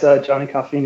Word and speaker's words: Johnny Coffeen: Yes, Johnny Coffeen is correct.